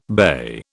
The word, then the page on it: BA: British English